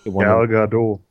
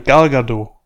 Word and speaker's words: Gal Gadot: Gail Gadot. – Gail Gadot. Spielt Einfach so, wie es da steht. Ja, Gail Gadot. Nein, Gal Gadot. Was? Gal Gadot? Ja. Gal Gadot. Die spricht sich Gal Ga oder Gado?